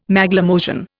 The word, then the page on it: Maglemosian: Indian